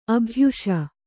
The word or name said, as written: ಅಭ್ಯಾಸ